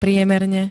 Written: priemerne

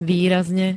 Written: výrazne

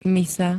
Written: misa

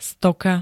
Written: stoka

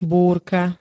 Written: búrka

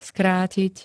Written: skrátiť

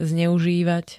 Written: zneuzivat

